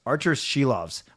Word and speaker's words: Silovs: And just before, yeah, I like, let's move from goaltender out, but just one thought about Silovs, or Shelovs, as they're both calling him now on TV and the radio, Archer's Shelovs, I guess is the proper way to say it.